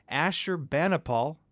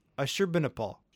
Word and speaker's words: Ashurbanipal: Ashurbanipal. – Ooh. – Ashurbanipal.